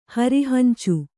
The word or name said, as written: ಹರಿಹಂಚು